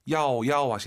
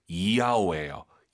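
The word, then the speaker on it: 이야오 (要): YAO 발음할 때 한국인들이 보통 야오 야오 하시는데요. 틀린 발음이에요. Y를요 항상 I로 바꾸세요. 정확히 발음하면요 이야오예요. 이야오. 이걸 빨리 발음해볼게요. – 야오. – 네, 절대 야오 야오 아니에요.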